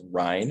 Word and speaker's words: Rein: It's Rein. It rhymes with wine.